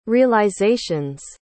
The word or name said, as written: realization's